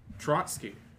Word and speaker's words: Trotsky: Stotsky or something? I may be saying that wrong. I wanted to find it first before I made myself look stupid by saying it wrong. But Trotsky.